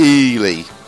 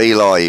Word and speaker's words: Eli: Regarding my brother, the twat, his name is pronounced Ely. It's short for Elijah. – Ely. – Oh, Eli, he means.